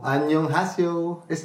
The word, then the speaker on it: 안녕하세요: We are in South Korea, so 안녕하세요.